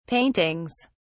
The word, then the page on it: paintings: British English